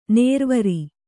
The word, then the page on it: ನೇರ್ವರಿ: nērvari